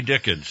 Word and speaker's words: Dickens: Well, just as little Jimmy Dickens said, which rhymes with chickens, he said that whenever they have guests over, he gets the north end of a chicken flying south for his part.